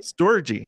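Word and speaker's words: storge: Is that pronouncing right? – Storgy. – Okay.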